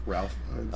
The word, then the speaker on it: Fiennes: But it's more just I think it's pronounced Ralph Fiennes.